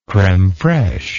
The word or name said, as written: creme fraiche